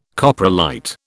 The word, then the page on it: coprolite: British English